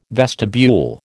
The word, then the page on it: vestibule: American English